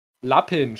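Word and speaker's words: Lupin: Das äh, hab Le Pen habe ich jetzt durch. Lappin spricht man das aus.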